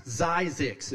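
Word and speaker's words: Zzyzx: It's Zyzix. Zyzix is how you pronounce that.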